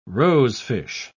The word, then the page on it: rosefish: British English